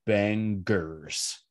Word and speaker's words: bangers: Which means every episode of Last Believer podcasts a podcast about Good Riddance that we have left will be composed entirely of bangers, hard G.